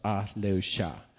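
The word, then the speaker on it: Ah-Leu-Cha: Det stavas A-H bindestreck L-E-U bindestreck C-H-A. Jag tror det uttalas Aleusha. Om det är någon som vet vad det betyder så får ni gärna komma fram redan.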